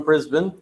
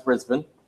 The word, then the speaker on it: Brisbane: Yep, so you came in from Brisbane, right? – Yep. – And you're the reason I know it's pronounced Brisbane.